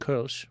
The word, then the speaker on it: Kölsch: Kolsch is, it's actually technically pronounced Kölsch with the umlaut over the O.